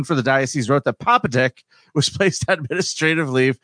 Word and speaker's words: Popadick: Papadick. – Yep, and you are pronouncing that correctly. – Yes, I am. – It is Papadick is his name. – P-O-P-A-D-I-C-K.